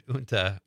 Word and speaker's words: junta: You used the word junta, which is spelled J-U-N-T-A. And I remember a classmate of mine in high school was reading and he said junta because he missed the lesson the day before where the rest of us learned how to pronounce it.